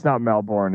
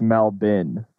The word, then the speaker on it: Melbourne: On Twitch, they're like, yo, it's not Melbourne. It's Melbin. I'm like, oh. – I guess they know best. – I guess they know best. I guess the R is silent.